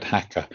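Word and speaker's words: Jaca: It's spelt with a J, but it's said Hacker.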